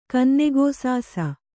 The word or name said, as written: ಕನ್ನೆಗೋಸಾಸ